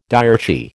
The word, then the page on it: diarchy: American English